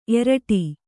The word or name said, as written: ಏರಿಡು